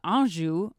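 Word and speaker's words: Öncü: Tolga Anjou, I looked that up. That's how you pronounce it.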